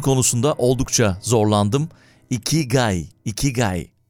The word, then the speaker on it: ikigai: It's pronounced ikigai. You lift your cheeks like you're smiling.